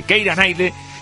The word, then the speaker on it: Keira Knightley: Bueno, vamos a decirlo así, Keira Knightley, que cumple hoy 39 añitos.